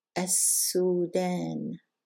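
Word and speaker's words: As-Sudan (السودان): We're not going to pronounce the lam and we're going to double the next letter. As-Sudan.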